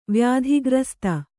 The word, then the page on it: ವ್ಯಾಧಿಗ್ರಸ್ತೆ: vyādhi grasta